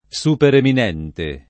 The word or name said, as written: supereminente